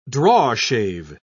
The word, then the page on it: drawshave: British English